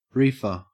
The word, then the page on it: reefer: Australian English